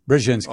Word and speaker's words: Brzezinski: And two of its board members over the years who have been such luminaries as Zbinsky. Now that he's gone, I can't say his name. Did I get it? That's close. – Brzezinski.